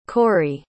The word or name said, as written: Corey